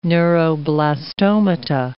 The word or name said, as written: neuroblastomata